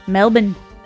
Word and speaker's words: Melbourne: And yeah, I believe they're from Melbourne. – Melbourne? – Melbourne. That's how they say it over there.